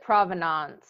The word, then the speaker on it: provenance: Sherry pronounced it provenance. – Earlier, and Deborah said, no, it's sort of like finance in finance.